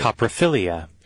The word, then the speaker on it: coprophilia: No, I just looked it up, dictionary, and this is how they pronounce it. – Coprophilia. – There you go.